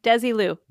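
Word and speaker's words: Desilu: Desi Lou.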